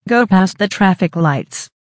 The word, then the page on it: go past the traffic lights: American English